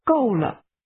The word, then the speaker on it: 够了: This is pronounced with two falling tones. Say, it's enough. – 够了。